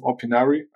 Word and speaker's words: Opinari: Gretchen is a founder and the CEO of Opinari.